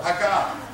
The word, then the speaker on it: Hagah: Hagah. Turn to somebody and say, Hagah. Accent on the second syllable. Hagah.